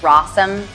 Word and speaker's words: Rossum: By the way, my name is Rossum, rhymes with possum or awesome, whichever you choose.